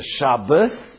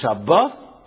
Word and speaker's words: Shabbat: So there's a Shabbos. It's not this, it's Shabbat, other mitzvah, or the Shabbat. So the Sfaradim only say Shabbat, but really there's two.